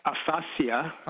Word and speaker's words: aphasia: And before I go, just quickly, the proper pronunciation of aphasia and aphantasia, which is the inability to visualize things in your brain, but that's not a subject altogether, would be like aphasia.